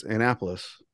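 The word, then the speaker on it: Annapolis: You're still not saying it right, are you? – Indianapolis. That's all I can think of is that commercial. – Am I saying it wrong? – No, it's Anne-apolis. – Wait. Is it Anne-apolis or Anne-apolis? – It's Anne-apolis. – Yeah, but what is he saying? Anne-apolis. He's saying Anne-apolis.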